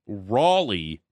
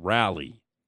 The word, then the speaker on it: Raleigh: It's pronounced Raleigh, not Rally. Raleigh. – Rally. – Hint number four. – Rally is like a car.